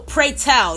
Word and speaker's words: Pretel: Because I feel like it's Petrel, but I don't know. Let me double check, because I don't want to be telling y'all no false information or whatever. So let me make sure I'm saying his name correctly, because it's... Oh, Pretel. Excuse me.